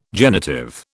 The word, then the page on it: genitive: British English